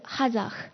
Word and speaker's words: Hazak: 那我觉得是 Hazak，然后因为它两个连着念，它有一个升调，一个降调。